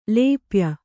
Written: ಲೇಪ್ಯ

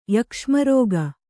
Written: ಯಕ್ಷ್ಮರೋಗ

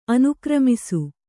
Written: ಅನುಕ್ರಮಿಸು

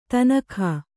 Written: ಧೇನುಕ

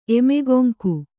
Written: ಎಮೆಗೊಂಕು

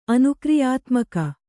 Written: ಅನುಕ್ರಿಯಾತ್ಮಕ

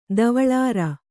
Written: ಧವಳಾರ

